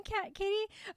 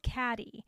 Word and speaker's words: Cady: Her name is spelled C-A-D-Y. It's spelled Caddy. – Mm-hmm. – So every time I look at it, I think Caddy, even though I know it's Katie.